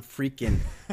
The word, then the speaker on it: Freakin': You got it exactly right. John Freakin' Muir Pod. There's no G at the end of freaking, so.